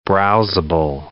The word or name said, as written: browsable